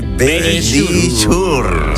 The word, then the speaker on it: Verisure: Nuestros amigos de Verisure. – Ahí lo habéis dicho divinamente.